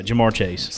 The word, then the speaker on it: Ja'Marr Chase: So it's like, you know, somebody will draft Ja'Marr Chase. Did I say that right? Yeah, Ja'Marr Chase.